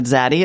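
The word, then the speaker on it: zeyde: On your recent episode, you discussed the word zaddy. It sounds a lot like the Yiddish word zaddy, which rhymes with the name Sadie.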